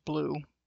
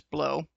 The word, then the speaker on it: Bleau: Uh, it might be pronounced blue, uh, but it's if, if we're doing the strict French, then it's blow and its uh, uh, headline is 12th uh, of September 2022.